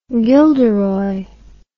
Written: Gilderoy

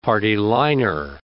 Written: party-liner

